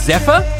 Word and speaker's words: Z8PHYR: You also heard in there, I don't know how to pronounce this, but it's spelt Z, number 8, P-H-Y-R. So I'm assuming it's Zephyr. – Yes.